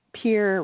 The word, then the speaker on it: Pierre: And while they're normally, these conferences are normally in Pierre, which is the South Dakota state capital, and it is pronounced peer, rhyming it with deer.